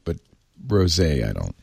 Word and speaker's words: rosé: I'm so glad it's pronounced rosé.